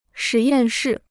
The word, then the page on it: 实验室: shí yàn shì